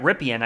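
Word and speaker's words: Rypien: That's somehow another interesting thing, because could Brett Ripien, I think is how you pronounce the last name. Yes.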